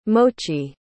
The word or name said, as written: mochi